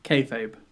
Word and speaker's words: Kayfabe: Am I pronouncing that correctly? – Kayfabe. – Kayfabe. – That's what it sounds like.